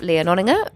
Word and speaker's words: Lea Nonninger: So, first up, we have Lea Nonninger, research analyst at Business Insider Intelligence.